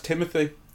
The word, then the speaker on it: Timothée: I think his name is pronounced Timothy, despite the way it's spelled. I've heard him say it.